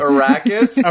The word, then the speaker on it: Arrakis: It's Arrakis.